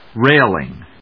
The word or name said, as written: Railing